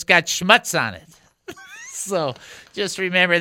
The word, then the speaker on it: schmutz: Some people pronounce it schmutz, but it's really like schmutz, schmutz. And you're thinking, what is that, right?